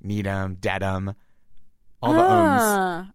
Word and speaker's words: Needham: I was thinking Needham. – Yep, that was what I thought. – Because it ends with H-A-M, but it's pronounced M.